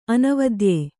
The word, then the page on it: ಅನವದ್ಯ: anavadya